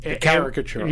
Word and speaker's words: caricature: Where like it's like oh, the racist character is so incredibly racist, and it's just like almost a character. Mm-hmm. It's not really a character, right? – Caricature. Yeah. Am I saying it wrong? – You're saying character. Caricature.